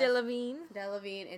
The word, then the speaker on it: Delevingne: Delevingne, and Taylor Swift.